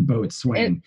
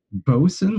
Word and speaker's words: boatswain: Which I didn't know how that was actually pronounced. I thought it was boatswain because it was written boatswain. – It looks like it. – Bosun? Like seriously? Bosun? You're going to tell me it looks like boatswain and it says, and you say bosun?